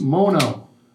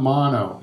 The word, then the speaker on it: Mono: Number one, it's pronounced Mono, not Mono. – It's confusing. – Right? – Farmers say Mono.